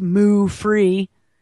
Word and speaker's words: Muufri: A San Francisco startup called Mufri, M-U-U-F-R-I, pronounced Moo-free, recently announced that it received $2 million to develop its own cowless cow milk.